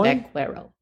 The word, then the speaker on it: vaquero: Vaquero.